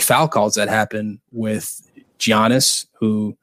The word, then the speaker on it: Giannis: Giannis. – Giannis.